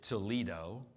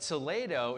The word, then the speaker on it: Toledo: Now, it's about an hour, I think, outside of Madrid, and uh, there's a sister city in the US. We pronounce it Toledo, Ohio. Uh, but Toledo is actually a first century city.